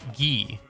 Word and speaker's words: Guy: a cute teal Nokia 3410 that we nicknamed Guy, which is the French pronunciation of Guy, a frequent character in our French textbook.